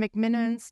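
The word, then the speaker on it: McMenamins: I say McMiniman's wrong. Did I say it right that time? – You did that time.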